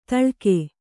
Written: ತಾಳಗೆ

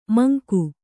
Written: ಮಂಕ

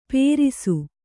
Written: ಪೇರಿಸು

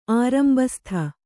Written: ಆರಂಬಸ್ಥ

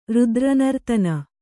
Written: ರುದ್ರನರ್ತನ

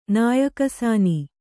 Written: ನಾಯಿಕಸಾನಿ

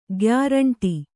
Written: ಗ್ಯಾರಂಟಿ

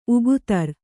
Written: ಉಗುತರ್

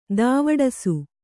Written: ದಾವಡಸು

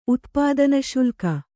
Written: ಉತ್ಪಾದನಶುಲ್ಕ

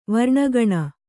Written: ವರ್ಣಗಣ

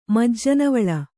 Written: ಮಜ್ಜನವಳ್ಳ

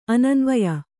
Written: ಅನನ್ವಯ

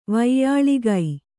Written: ವ್ಯಳೀಕ

